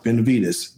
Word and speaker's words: Benavidez: Yeah, Benavidez.